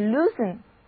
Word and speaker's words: loosen: 那如果我们把 loose 的结尾字母 e 去掉，然后换上 en 这个动词后缀，得到的单词就是 loosen， L-O-O-S-E-N，后缀中的字母 e 不发音，因为 loose 是一个形容词，表示松的、松散的，所以 loosen 这个动词的意思很简单，就是解开、松开。